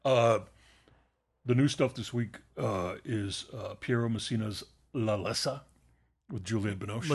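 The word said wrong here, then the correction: L'attesa: La Tessa.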